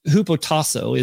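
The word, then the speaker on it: hupotasso (ὑποτάσσω): It's hupotasso is the Greek pronunciation, but the first word is hypo, which means underneath.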